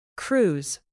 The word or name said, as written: cruise